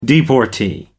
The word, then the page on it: deportee: American English